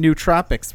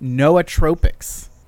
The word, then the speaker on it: nootropics: First, we have a feedback from John Clark, who says we are pronouncing nootropics wrong. It's actually pronounced nootropics. So... I don't know why they spell it that way, but nootropics is how you say it.